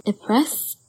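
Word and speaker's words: Après: Before I delve any further, the wine I am sipping on at midnight is called a press a press is spelled A P R E with an accent S.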